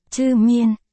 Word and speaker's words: Chư Miên: Một, ZM, Chi Miên, Chư Miên, phát âm là, Chư Miên, có cách viết tắt là ZM, cách chương.